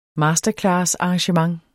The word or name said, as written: masterclassarrangement